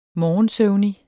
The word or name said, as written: morgensøvnig